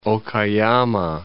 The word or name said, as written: Okayama